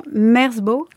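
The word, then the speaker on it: Merzbow: Donc c'est le groupe, dites-moi si je me trompe dans la prononciation, Merzbo. – Oui.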